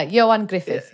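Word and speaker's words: Ioan Gruffudd: I even Googled the pronunciation of Johan Griffith. – Yeah. – Yeah. Is that right? – Yeah, Johan Griffith.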